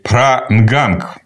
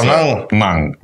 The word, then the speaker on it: Пранганг: Считается, что статуи амулеты Пранганг его зовут. Ну, буква «Г» так прям четко не произносится. Она говорится... – Пранганг.